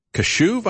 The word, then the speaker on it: Kashuv: And we have Kyle Kashuv, I think is the way you pronounce his last name, K-A-S-H-U-V.